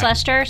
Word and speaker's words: Leicester: Well, see, and I thought Lester would have been real because it's spelled, like, it's Leicester, spelled Lester, or pronounced Lester.